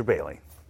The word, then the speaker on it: Boehly: Actually, it's technically pronounced more Bailey than Boehly.